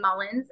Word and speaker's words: Mullins: It's at Talk Mullins, T-A-L-K, and then my last name, Mullins, M-U-L-L-I-N-S. – At Talk Mullins, M-U-L-L-I-N-S.